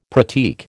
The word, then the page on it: pratique: American English